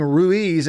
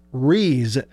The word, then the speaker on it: Ruiz: Blake also said that the kicker, Brandon, we were saying Ruiz, and it's spelled Ruiz, R-U-I-Z, but we found out on the broadcast from the team that Brandon says his last name, the actual correct pronunciation is Rees, as almost as if we're just looking completely past the U.